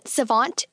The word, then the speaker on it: savant: And second, savant is pronounced like want, and not like ant or pant.